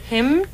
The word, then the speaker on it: hymnal: Do you pronounce the N? – It's a hymn. – Hymnal.